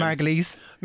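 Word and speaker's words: Margolyes: No, what I would invite Miriam, how are we pronouncing her surname? – Margulies.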